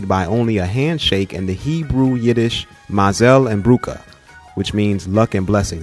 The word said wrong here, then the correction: mazel and brucha: Did I say that right, Stephen? – Yeah, pretty close. – So rather than being written... – Mazel and Brucha, yeah.